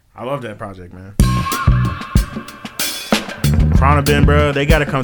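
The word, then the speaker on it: Khruangbin: Cronabin. That's how you say it. They got a weird name, but Cronabin.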